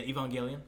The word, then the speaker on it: Evangelion: Because I always say, I look at it like Evangelion. – Yeah, Evangelion. – Yeah, okay. That's how you really say it.